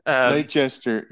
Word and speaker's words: Leicester: L-E-I-C-E-S-T-E-R. How would you say that? – Yeah, so I always want to say it's Leicester, but I think it's Leicester City. – Like yeah, Worcestershire. – It's not French, Leicester.